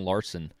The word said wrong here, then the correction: Larsa: That's how you say it, Larsa or Larson? – It's Larsa.